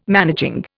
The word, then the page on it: managing: Indian